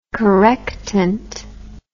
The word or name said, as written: correctant